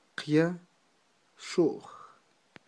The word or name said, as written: qyaxu'j